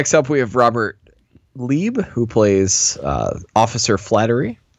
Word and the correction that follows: Flaherty: And I don't know if I pronounce that right. Flaherty? – Yeah. – Flaherty.